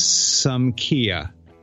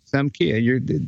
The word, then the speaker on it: Sumkea: It is not the same as some Hyundai or some Yugo. It is some Kia, but it's spelled S-U-M-K-E-A. – That is, okay, that's a little confusing. I get it. S-U-M-K-E-A.